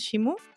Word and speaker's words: Shimu: Shimu joyas, ¿lo he dicho bien? ¿Es Shimu? Sí.